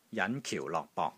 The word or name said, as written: JAN5 KIU4 LOK6 BOK3